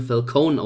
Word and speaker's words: Falcone: Dann haben wir natürlich die Mafia vertretend, die Figur Carmine Falcone der in diesem Film Falcone ausgesprochen wird und nicht mit I am Ende, also Falcone oder mit englischem E.